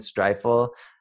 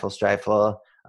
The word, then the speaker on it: Streifel: That's Nathan Streifel, rhymes with rifle, Streifel.